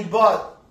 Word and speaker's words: both: Dice Roxana Navarro Mesa, buenos días Manuel, God bless you both. ¿Cómo se pronuncia, eso? – Both. – Both. ¿Como bote? – Pero sí, pero más suavecito. – Como el both.